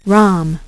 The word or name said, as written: ROM